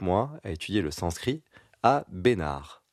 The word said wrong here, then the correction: Bénarès: Bénarès, parce que c'est dans le sud. – On prononce toutes les lettres, monsieur Bénarès.